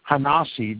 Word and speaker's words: HaNasi: And you can do your own research on this, and I'll spell it for you if you care to. It's H-A space N-A-S-I hyphen T-H-E space Prince. Hanasi the Prince.